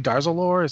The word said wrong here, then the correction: Dazar'alore: Is that how you pronounce that? – Darzalore. I don't know. – Dazar'alore. – Dazar'alore. Thank you.